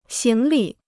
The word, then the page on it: 行李: xíng li